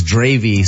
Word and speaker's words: Dravet: It's also Dravet, known as Dravy.